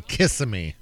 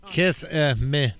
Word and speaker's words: Kissimmee: He told Osceola County investigators that he did indeed attempt to kill multiple people at the Friendly Village Inn and Hotel in Kissimmee.